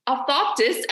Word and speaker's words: orthoptist: However, we've said the word optop. Oh my gosh, I keep saying it wrong. Orthoptist. I don't know why I cannot say this. Orthoptist. Yep. Yes, I say the R.